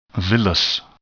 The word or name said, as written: villous